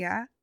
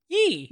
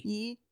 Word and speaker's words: Ye: Is it yeah? – It's no. Yee. – Yee. – Yeah. Like Yeezys. – Oh, okay. – Right? It's yeah. – Yee.